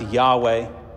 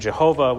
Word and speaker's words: Yahweh: It's most likely pronounced Yahweh, but there was a reverence toward it, and they didn't want to utter the name of God.